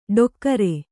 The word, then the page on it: ಡೊಕ್ಕರೆ: ḍokkare